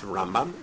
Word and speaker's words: Rambam: Apparently, Ashkenazim, don't have a problem distinguishing. It's Rambam and Ramban.